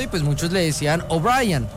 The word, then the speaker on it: O'Brien: No, no es O'Brien, O'Brien. – O'Brien. La gente cree que por cómo se escribe así, sí, se dice O'Brien. No, es O'Brien.